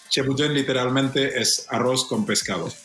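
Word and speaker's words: Ceebu jën: No sé si lo he pronunciado bien. Chobo diane. – No lo has pronunciado. – Me lo ha dicho un... – No lo que querías. – ¿Cómo es la pronunciación? – Chebujen. – Ah, ¿ves? Bueno, casi.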